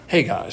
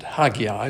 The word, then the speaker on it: Haggai: We're in the book of Haggai. It's pronounced Haggai, spelled H-A-G-G-A-I. This is our first lesson in Haggai. Some people pronounce it Haggai, but it's actually pronounced Haggai or Haggai.